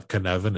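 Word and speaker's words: Cynefin: If you want to study a Kinevan, it's spelled with the C. It's a Welsh word.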